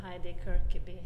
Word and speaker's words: Heidi Kirkeby: Well, in English, I suppose we could say Heidi Kirkeby.